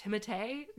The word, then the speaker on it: Timothée: Yeah, and he dresses well, like, and his name is pronounced Timothée.